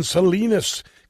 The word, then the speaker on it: Salinas: I want to go to Don in Salinas, California. Rhymes with weenus, which is the skin on your elbow.